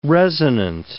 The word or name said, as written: resonant